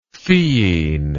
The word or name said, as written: theine